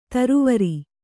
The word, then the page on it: ಥರಾವರಿ: Kannada